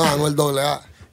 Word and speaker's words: Anuel Doble A: Yo, Ebro, Laura Rosenberg, give it up for Puerto Rico's own Anuel Doble A. Am I saying it right? Or is it Anuel? – No, Anuel Doble A.